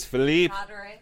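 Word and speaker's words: Philippe Carlier: Felipe Carlier. Carlier. Carlier. – Carlier. is Philippe. Carlier. – Philippe. Philippe. Carlier. Yeah. French.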